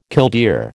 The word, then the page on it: killdeer: American English